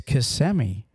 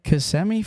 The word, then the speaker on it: Kissimmee: And that is Kissimmee. Kissimmee, Florida, especially the little kids and everybody, when they see it, they say Kissimmee. – Kissimmee. – Kissimmee. – Kissimmee, Florida.